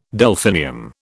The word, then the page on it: Delphinium: British English